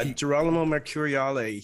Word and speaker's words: Girolamo Mercuriale: Yeah, Girolamo Mercuriale.